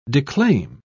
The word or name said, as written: declaim